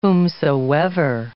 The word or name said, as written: whomsoever